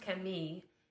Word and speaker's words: Camille: Well, what's interesting about my name, as I mentioned, that it's French and I was born in France, so it's pronounced Camille,